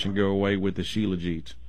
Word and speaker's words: shilajit: Yeah, and so I think it's spelled S-H-I-L-A-J-I-T, but I think you pronounce it shilajit.